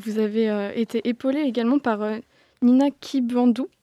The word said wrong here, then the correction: Kibwanda: Je que je le prononce. Kibwanda. – Kibwanda, merci.